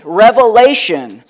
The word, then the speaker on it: Revelation: Maybe you've pronounced the book Revelations with an S on the end. That's not what this book is.